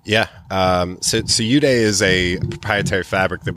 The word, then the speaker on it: Eudae: And walk me through, like in particular, you guys tout, U-Day, am I saying that right? – You are. – E-U-D-A-E, as a method of manufacturing clothes.